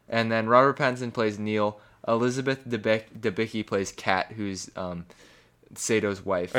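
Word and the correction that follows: Sator: It's Sador.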